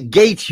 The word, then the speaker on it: Gaetz: Yeah, Matt Gaetz, you freak.